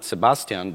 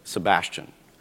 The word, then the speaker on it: Sebastian: So not Sebastian, but Sebastian.